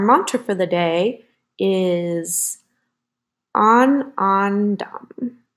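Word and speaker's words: ānandam: and that's spelled A N A N D A M. And it's to restore inner joy and contentment. I'm going to pronounce it one more time on, on, dom.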